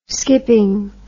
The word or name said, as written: skipping